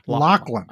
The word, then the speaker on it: Lachlan: Lachlan.